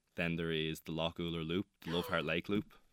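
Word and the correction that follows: Lough Ouler: So Loch Uller. I never Loch Owler. Loch Owler. Sorry, that was my pronunciation.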